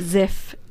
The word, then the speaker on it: Ziff: And I'm just going to spell it because it sounds a bit like, you know, it's L-A-R-A. Okay, so it's Lara, not Laura. And it's Ziff, Z-I-F-F.